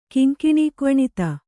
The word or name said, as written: ಕಿಂಕಿಣೀಕ್ವಣಿತ